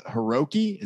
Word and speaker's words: Hiroki: It's called Hiroki. Is that right? Did I say it right? – That is correct.